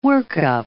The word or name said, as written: work-up